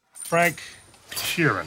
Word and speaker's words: Frank Sheeran: Frank Sheeran. Am I saying that right? Yeah, you said right.